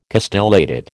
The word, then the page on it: castellated: American English